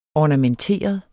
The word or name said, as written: ornamenteret